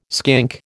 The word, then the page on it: skink: American English